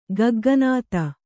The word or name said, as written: ಗಗ್ಗನಾತ